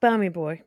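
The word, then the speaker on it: Bamigboye: You know, you don't pronounce the G, it's just Bamigboy. – Oh, right, Baz Bamigboy, right? Yeah, is that right? – Yeah, like Danny Boy.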